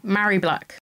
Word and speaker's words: Mhairi: Mhairi Black.